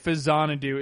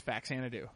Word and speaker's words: Faxanadu: Well, the reason you pronounce it Fazanadu is because it's part of the Xanadu series. That's why. Z-A-N-A-D-U is Xanadu, actually, or Xanadu. And this is from that. But if you're American, you've never seen any of the Xanadu games, so you say Faxanadu because it starts with F-A-X, and there's only one way to pronounce that in English, which is Faxanadu.